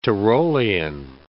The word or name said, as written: Tyrolean